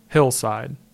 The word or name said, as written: Hillside